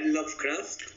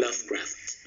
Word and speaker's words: Lovecraft: En realidad el grupo se llamaría Lovecraft, pero es como un... – Lovecraft. – Exacto, como una... – Lovecraft. – Como una reducción.